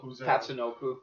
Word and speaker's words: Tatsunoko: Tatsuwaka Naka Nakarama. – Oh, Tatsunoko. – Tatsu, yeah.